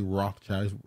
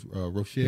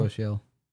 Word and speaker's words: Rochelle: Is that I don't want to say Rothschild. It's Rochelle. – Yeah, Rochelle.